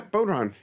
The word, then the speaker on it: bodhrán: I even persuaded Scott to add some, I forget how that's pronounced, a bodhrán?" – Yes. Yeah, bodhrán. Yeah.